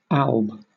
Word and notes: alb: British English

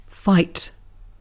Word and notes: fight: British English

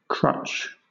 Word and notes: crutch: British English

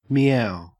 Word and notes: meow: Australian English

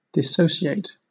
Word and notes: dissociate: British English

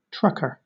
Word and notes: trucker: British English

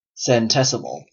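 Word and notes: centesimal: Canadian English

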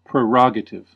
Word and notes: prerogative: American English

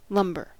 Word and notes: lumber: American English

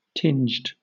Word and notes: tinged: British English

